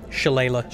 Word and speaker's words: Shillelagh: How do I say that? – Shillelagh. – Shillelagh? – Shillelagh.